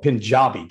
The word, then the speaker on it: Punjabi: But what I read in there was that they spoke, and your grandmother too, a dialect called Punjabi. Did I pronounce it right? – Correct.